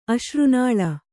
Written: ಅಶ್ರುನಾಳ

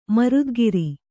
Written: ಮರುದ್ಗಿರಿ

